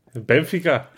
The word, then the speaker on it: Benfica: En hij zegt ook gewoon Benfica. Benfica. Ja, ja. ja, goede klemtoon, hè?